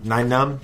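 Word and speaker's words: Nien Nunb: Nien Noom, I can't ever pronounce his name. But anyway, Nien Noom. – Nien Noom. – There we go.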